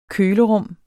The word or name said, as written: kølerum